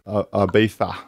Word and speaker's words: Ibiza: Ibiza.